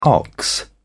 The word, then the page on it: ox: British English